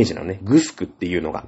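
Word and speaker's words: 城: 財産っていうのがあって、貧富の差がはっきりすると、じゃあ支配するもの、されるものっていう感じで、沖縄にさ、いろんなとこにグスクってあるじゃない。ね、なんか白って書いてグスクって読むんだけど、沖縄では。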